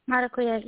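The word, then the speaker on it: maracuyá: Maracuya? Is that how you pronounce it? Hope? – Maracuya, yes, the passion fruit.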